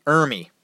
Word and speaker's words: Ermey: R. Lee Remy. I'm sorry. Did I say his name wrong? I did. All right. R. Lee Ermey.